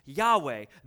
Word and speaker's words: YHWH: But if you take it from Hebrew just over into English, it simply is four letters, YHWH, pronounced Yahweh.